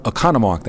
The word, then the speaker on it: Oconomowoc: That is Ken Rosenau from Oconomowoc. That's how you properly pronounce it, by the way.